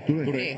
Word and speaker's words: Touré: Mohamed Touré. ¿Lo dije bien? – Touré.